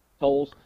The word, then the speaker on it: Towles: Tolles. Depends on who you ask, but yeah, Tolles. I like Towles. – If it's the French pronunciation. – It looks like Towles to me. It should be Towles, but I think he likes Tolles.